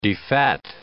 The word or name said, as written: defat